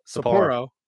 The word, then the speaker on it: Sapporo: Did I say that right? Sapporo. Yeah, Sapporo.